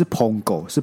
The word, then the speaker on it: 捧狗: 棒狗什么意思啊？干，不是，是捧狗，是捧狗。 – 对，我想，但是我刚才想说，你把它怎么会把它念成棒狗？